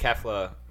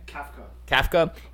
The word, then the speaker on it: Kafka: And apparently he picked up the playbook quickly because Mike Kefla. Kefla? Kefla? How do you say his name? – Kafka.